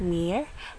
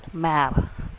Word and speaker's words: mère: So we have here, la mère, it's not mere, but mère. Okay, nashe H, invisible H between E and then the R. La mère, same with the père.